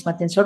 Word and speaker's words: Matienzo: En LinkedIn también me pueden encontrar como Denise Matenso Rubio. En Instagram estoy como denise.matienzo. Recuerden siempre que mi nombre se escribe con una N, con una S, con E al final y mi apellido con Z.